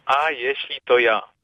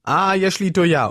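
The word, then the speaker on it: A jeśli to ja: Wie spricht man das korrekt auf Polnisch? – A Jesli to ja. – A Jesli to ja.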